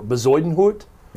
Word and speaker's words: Bezuidenhout: We had the fun with this pronunciation before. Bezuidenhout? – Yeah, you nailed it.